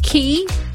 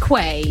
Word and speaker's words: quay: It's not really a silent letter, but I have an issue with the word key, as in Queensway Key. – Ah. – What is that? Queensway Quay.